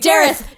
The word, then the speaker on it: Jareth: Oh, it's Jared. Oh. It's the Goblin King. Jareth. I said his name wrong.